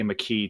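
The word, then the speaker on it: Makee: Is it do we pronounce it Mackay? – You know, I wasn't really sure through the first couple of weeks, but I heard her say McKee today, almost like Anthony McKee is how I'm remembering it.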